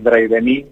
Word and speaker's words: Dreidemie: Lo que pasa es que tenemos una versión mendocina ah. para hacerlo más fácil, pero sí, le decimos Drey de mí, pero es, el apellido.